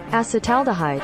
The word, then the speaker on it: acetaldehyde: Acetaldehyde.